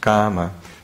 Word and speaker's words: kama: This is kama. So it's kama, but it's a K long A M A.